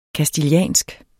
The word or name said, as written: castiliansk